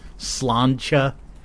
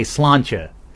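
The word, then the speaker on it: Sláinte: You know that word, S-L-A-I-N-T, with a bunch of accent marks on it? I happen to know. I think it's pronounced something in the vicinity of Slantia. Why not spell it S-L-O-N-C-H-A, Slantia?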